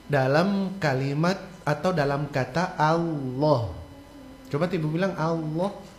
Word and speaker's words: Allah: Allah. – Yes. Ya, jadi A itu tipis, lohnya itu yang tebal. Karena huruf lamnya yang ditebalkan. Fakhimil lama min ismillah, ya. Jadi cara bacanya Allah.